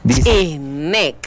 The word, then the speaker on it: Chihnek: Chihnek.